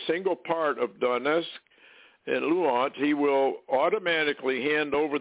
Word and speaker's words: Luhansk: Luhansk. Luhansk.